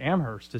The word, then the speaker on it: Amherst: You know how to say Amherst. – Now, I mean, apparently Amherst is how you say it.